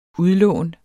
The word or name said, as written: udlån